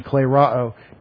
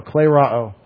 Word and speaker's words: klerao: Spent about a year of Thursdays on klerao. K-L-E. That's a long E-R-O. Long O. Klerao. Klerao.